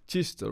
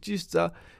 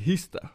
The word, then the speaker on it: Kista: Så jag kunde inte uttala Kista, right? Jag säger Hista.